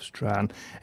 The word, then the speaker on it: Strahan: You've given me the pronunciation, so I assume myself Strahan. S-T-R-A-A-N is the pronunciation. The actual spelling of the place is S-T-R-A-H-A-N.